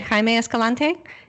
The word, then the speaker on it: Jaime Escalante: So in your book, Escalante, The Best Teacher in America, you talk about, I hope I'm pronouncing it right, Jaime Escalante? – Exactly right.